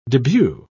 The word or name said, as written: debut